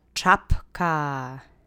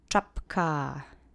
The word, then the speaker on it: czapka: Accent is on that first syllable, so really punch up the ch for me. Let's say it together three times. Czapka. Czapka. Czapka.